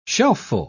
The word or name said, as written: shelfful